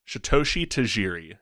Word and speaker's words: Satoshi Tajiri: Pokemon was created in Japan by game developer Satoshi Tajiri.